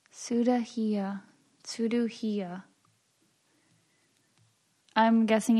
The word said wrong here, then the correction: cirugía: I say surugía. Cirugía. Cirugía. – Oh, you say it wrong too, and I still got it.